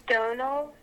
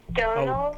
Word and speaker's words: Domhnall: Domhnall Gleeson, like tonal with a D. Tonal? Like tonal. Hold on, D- with a D. – Domhnall oh. – Gleeson, like tonal with a D. So Domhnall Gleeson, like Domhnall tonal Gleeson?